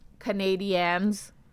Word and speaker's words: Canadiens: And yes, it's pronounced Canadiens. I know it's weird. – Canadiens. – But apparently that's correct. When I first heard it, I thought it was wrong too.